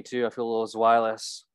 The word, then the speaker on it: Zweilous: I did my research about how the lures only attracted the, how do you say it, Zweilous? It's the second evolution of it. – I say Zweilous as well.